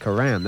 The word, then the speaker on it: Coram: I said Coram. That's how you're supposed to pronounce it. – Really? I didn't know that. I always thought it was Coram. – Well, actually, most people throughout the world pronounce it the way you said it. – Coram? – Right. But people on the North Shore of Long Island always call it Coram.